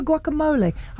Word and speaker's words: guacamole: You've got pistachio guacamole. I probably said it incorrectly. – No, that was actually perfect pronunciation.